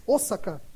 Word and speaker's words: Osaka: Porque quando ela volta essa segunda vez para o Japão, ela conhece lá em Osaka, não é Osaka, né? Interessante, você viu como que pronuncia em japonês? Osaka.